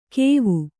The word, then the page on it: ಕೇವು: kēvu